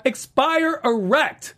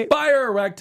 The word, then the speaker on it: Expire Erect: I will be hosting this panel as we talk all things Lucifer, Season 4, Episode 5, Expire Erect. Yes, uh-huh. – I said that correctly. – You did it right. – Expire Erect.